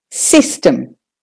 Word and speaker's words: system: And the next word is system. System. So the sis is louder and longer. System. And then you just do the T-E-M, short and quick.